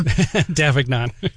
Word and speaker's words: Davignon: I feel like the toughest part of this interview might be correctly pronouncing your last name. – Davignon.